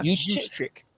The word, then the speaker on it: Juszczyk: Kyle Juszczyk. – Yeah, Juszczyk.